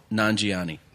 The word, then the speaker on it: Nanjiani: Nanjiani.